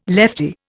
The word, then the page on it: leftie: Indian